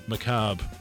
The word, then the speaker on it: macabre: Did I pronounce macabre correctly? – You did indeed.